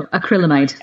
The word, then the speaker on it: acrylamide: So we've got acrylamide. Have I pronounced that right? – No, acrylamide. – Acrylamide. Artificial sweeteners, green tea, milk and dairy, pesticides, soy products, superfoods and tomatoes, all on the we're not really sure what's going on.